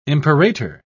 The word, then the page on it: imperator: British English